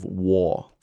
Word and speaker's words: Waugh: I am absolutely sure that there has been at least one war pun-based war book about the Waugh brothers, where war is spelled W-A-U-G-H.